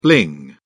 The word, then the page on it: bling: British English